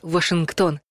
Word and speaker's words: Вашингтон: В русском языке столицу США принято произносить «Вашингтон», точно так же и одноименный штат. А вот фамилии первого американского президента Джорджа Вашингтона ударение нужно ставить на первый слог.